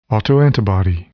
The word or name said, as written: autoantibody